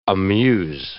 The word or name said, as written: amuse